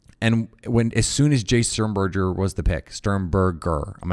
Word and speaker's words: Sternberger: I'm going to use it until it is in my brain that it's a hard G. Sternberger.